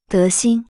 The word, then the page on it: 德馨: Dexin